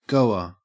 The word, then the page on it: goer: Australian English